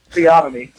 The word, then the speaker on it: theonomy: talking about law, talking about God's law, theonomy, and Jeremiah, that is how you say it, theonomy.